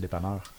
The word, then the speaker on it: dépanneur: How do you say dépanneur? – Dépanneur. That's how we say it.